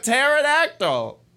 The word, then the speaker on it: pterodactyl: Pterodactyl. The P is silent.